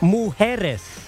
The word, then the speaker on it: mujeres: Mujeres.